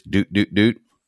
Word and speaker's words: Dutt: I do want to point out, of course, that you said Sanjay Dutt, which is not Sanjay Dutt. Dutt is, I guess it's kind of a Fabulous Freebirds thing. Is it a Michael P.S. Hayes? Dutt. Pronounce it right, please.